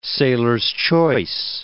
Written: sailor's-choice

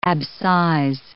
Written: abscise

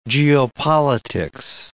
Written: geopolitics

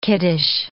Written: kiddish

